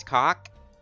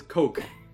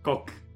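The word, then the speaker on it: coc: The music just says cock. – Viv, there's no K in it. It says coke. Coke.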